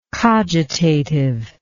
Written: cogitativeness

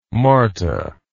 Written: marta

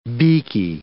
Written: beaky

